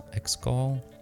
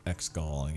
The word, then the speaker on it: Exegol: It's called Exegol. – Exegol, yeah.